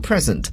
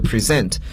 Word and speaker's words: present: 今天我们学到这个词的时候，大家可能觉得这个发音很特别，不像大家以前学到礼物这个词呢，它读作 present，可是今天呢却读作了 present。